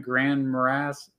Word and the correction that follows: Grand Marais: Is it Grand? How do you pronounce that? – Grand Marais, I think. Grand Marais, Minnesota, just south.